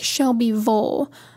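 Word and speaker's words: Shelbyville: but most names in the South, or at least in Tennessee, that have a ville at the end of it, it's Vole. So there's like Shelby Vole, McMinn Vole, like, I don't know why it's pronounced like that, but it is.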